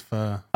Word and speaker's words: pho: I thought it was pronounced fur as well. – Okay. I think it's fur.